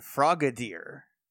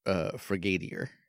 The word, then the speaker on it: Frogadier: I think it's pronounced Frigadier. – Frigadier. – Frigadier. It's French, you see. – It's like Frog and Cavalier with a D in it. – I thought it was like Bombardier.